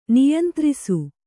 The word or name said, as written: ನಿಯಂತ್ರಿಸು